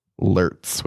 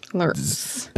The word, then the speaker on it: Lurtz: I know, but it's clearly the what did you say his name was? Lurch? – Lurtz with a Lurtz. – Lurtz.